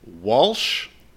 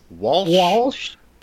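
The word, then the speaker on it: Whilce: I've heard it pronounced several times. It's Walsh Portasio. – What? – Yes, Walsh Portasio. I've also heard Welsh, or no, it can't be Welsh.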